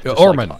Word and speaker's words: Ormond: Ormond. – Okay. Osmond, except with an R.